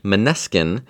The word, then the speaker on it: Måneskin: But this is a group called Måneskin. It's spelled M-N-E-S-K-I-N. It's kind of an odd spelling.